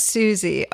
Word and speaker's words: Suze: I think it is Susie. Oh, we're going to get so many emails. I know it's spelled Suze, but I think it's pronounced Susie. – Oh, okay. – I know it's S-U-Z-E for sure.